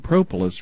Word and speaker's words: propolis: Propolis is almost like the word Polish, but just drop the H on the end of it, but put a P-R-O. P-R-O-P-O-L-I-S.